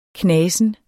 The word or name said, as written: knasen